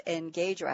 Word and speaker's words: Engajer: Let's kick off the party with Michael Castellano, the founder and CEO of Engager. I have to spell that again, E-N-G-A-J-E-R, Inc. We have to go for the French pronunciation.